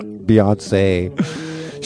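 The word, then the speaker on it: Beyoncé: How do you say it? Beyonce? – Yeah. – Beyonce.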